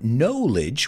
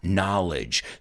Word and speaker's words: knowledge: It's not knowledge, which I understand why many students mispronounce it, because the verb is to know. But the word knowledge is knowledge. So I know a lot. I have a lot of knowledge. It's like the word south and southern. Sometimes when we add a prefix or a suffix, it changes the pronunciation of the vowel.